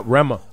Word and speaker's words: Rema: Yep, Rema.